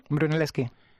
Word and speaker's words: Brunelleschi: Brunelleschi, ¿cómo se dice? – Brunelleschi. – Brunelleschi fuera la estructura más grande y también la más alta de Europa en su época.